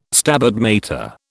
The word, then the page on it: Stabat Mater: British English